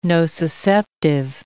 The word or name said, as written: nociceptive